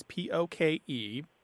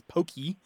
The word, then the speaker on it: poke: And now the Hawaiian word we're talking about here, to go back to the fish dish, is p o k e, pronounced basically as poke, although in the mouths of Anglophones, it usually comes out as pokey or poke. I have seen it have an accent on the E, but that is incorrect.